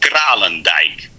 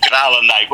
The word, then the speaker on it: Kralendijk: It's pronounced Kralendijk. – It sounds German. Yeah. Kralendijk? – Yeah. Kralendijk. Well, it's Dutch. It's Dutch.